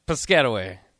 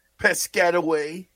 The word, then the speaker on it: Piscataway: Piscataway. – Piscataway.